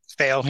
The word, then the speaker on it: Thayil: How do you say it? Kim Thayil? – Thayil. Yeah.